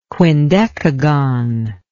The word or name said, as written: quindecagon